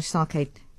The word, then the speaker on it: sake: Tickets include one 5-piece sushi platter and a sake. Is that how we pronounce it? It is sake, isn't it? Sake. 395 rand. And sake, yeah.